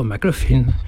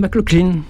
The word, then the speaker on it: McLaughlin: John McLaughlin, dans les bronches, pour rectifier ma prononciation de son joli nom. Ça se prononce comme ça s'écrit, effectivement.